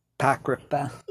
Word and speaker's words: Apocrypha: Apocrypha.